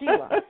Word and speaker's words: Shelia: Yes, it's spelled like Shelia or Shelia, but it's Shelia. But